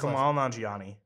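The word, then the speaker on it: Kumail Nanjiani: Okay, so for those who can't like be omnipotent and see what we're doing right now because we're behind a microphone, It's Camille Nungel. How do you say his Kamal Nanjiani.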